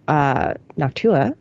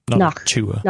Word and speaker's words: Noctua: So with a hard T, Tua, Noctua. – Noctua.